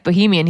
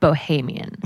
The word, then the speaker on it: Bohemian: I said, oh, is your wife Bohemian? He said, Bohemian. I've been saying it wrong.